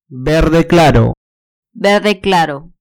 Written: verde claro

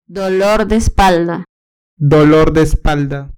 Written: dolor de espalda

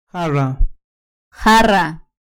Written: jarra

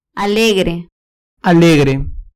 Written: alegre

